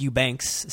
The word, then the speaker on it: Eubanks: So Kevin Eubanks, pronounced Eubanks.